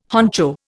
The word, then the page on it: honcho: Indian